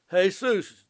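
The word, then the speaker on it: Jesús: Jesus, the J's are pronounced as H's.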